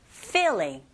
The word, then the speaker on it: feeling: Feeling. Feeling. 우리말처럼 필, 여러분, 필링이 아니라 필링은 벗기는 거예요. feeling. 윗니로 아랫입술을 살짝 앞에서 뒤로 긁어주시면 되고.